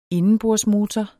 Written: indenbordsmotor

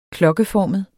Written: klokkeformet

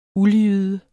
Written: uldjyde

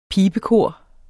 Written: pibekor

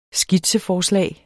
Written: skitseforslag

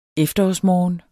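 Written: efterårsmorgen

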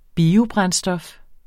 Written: biobrændstof

